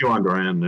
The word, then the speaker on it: Johan Duran: And their bullpen has two interesting arms in Johan. Is it John? How do I say his first name? – It's Johan Duran.